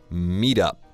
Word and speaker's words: meet up: Meet up. – Meet up 是 M-E-E-T 那个 meet，后面加 up 这个介词。 – That's right, but we pronounce it as meet up. – 就连读 T 和 U.